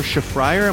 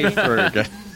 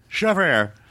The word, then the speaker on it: Shafrir: Anyway, our guest on the podcast today is Doree Shafrir. Am I saying that right? – Shafrir, actually. – Shafrir. The bane of your existence. – Indeed. – It's Doree Shafrir.